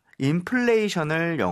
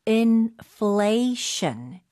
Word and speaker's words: inflation: 자, inflation을 영어로 발음이 중요하죠. – 네, 맞아요. P 소리가 아니에요. F 소리입니다. Inflation. Inflation.